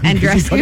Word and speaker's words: Andreescu: Andreescu.